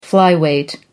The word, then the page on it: flyweight: American English